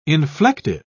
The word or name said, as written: inflective